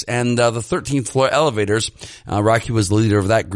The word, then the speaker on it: Roky: It's spelled R-O-K-Y, and, I'd been pronouncing it Rocky. Apparently it's Rocky, just without the C.